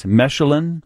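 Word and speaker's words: Mesulam: Dr. Sandra Weintraub is a neuropsychologist at Northwestern's Mechelen, is it kind of a soft S, Mechelen Center for Cognitive Neurology and Alzheimer's.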